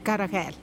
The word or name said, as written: karajed